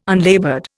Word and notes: unlaboured: Indian

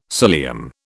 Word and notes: cilium: British English

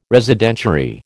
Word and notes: Residentiary: American English

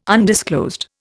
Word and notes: undisclosed: Indian

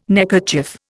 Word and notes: neckerchief: Indian

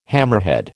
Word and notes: hammerhead: American English

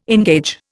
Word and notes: engage: Indian

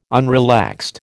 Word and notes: unrelaxed: American English

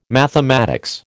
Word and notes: mathematics: American English

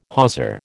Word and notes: hawser: American English

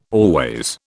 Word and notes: always: British English

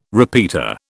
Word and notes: repeater: British English

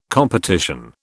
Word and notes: competition: British English

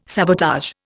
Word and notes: sabotage: Indian

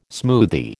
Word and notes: smoothie: American English

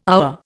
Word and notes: our: Indian

